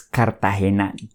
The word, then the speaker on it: Cartagena: Primero, quiero que aprendan a pronunciarla. Es Cartagena. C-A-R-T-A-G-E-N-A. Cartagena. No digan Cartagena, porque se van a dar cuenta que ustedes son turistas y les van a cobrar más. Es Cartagena.